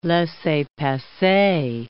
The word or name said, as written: laissez-passer